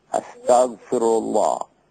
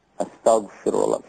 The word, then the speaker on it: Astaghfirullah: Astaghfirullah. Astaghfirullah.